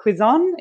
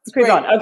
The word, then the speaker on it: Cuizon: And today on The Positive Effect, I'm featuring Almira Cuizon. Is that how you say your last name? – Yes, Cuizon. – Cuizon, okay.